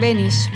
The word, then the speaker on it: Benisz: Supongo que es Benish, porque es polaco. – Ah, mira. – Pero igual no tiene ningún sentido pronunciarlo. – Benish. – Con la pronunciación original acá, ya lo castellanizamos.